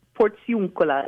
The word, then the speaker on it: Portiuncula: Portuncula Indulgence. What is the Portuncula? Am I pronouncing it right? – Portuncula. Portuncula means... Portiuncula.